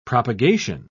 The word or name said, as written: propagation